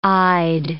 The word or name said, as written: eyed